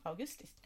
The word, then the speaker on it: August: Men August kommer från Augustus och ska uttalas som Augusti.